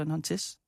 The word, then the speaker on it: Nantes: Ja, og han skal jo til den franske klub Nantes, tror jeg, man udtaler Nantes.